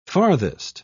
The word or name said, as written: farthest